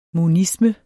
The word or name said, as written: monisme